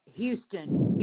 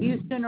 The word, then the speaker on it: Houston: It may be pronounced Houston. Houston or Houston. It's H-O-U-S-T-O-N.